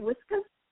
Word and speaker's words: Whiskas: I think I said that right, Whiskas.